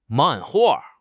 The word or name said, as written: 漫画